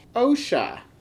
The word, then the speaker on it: OSHA: Some of those themes may be OSHA.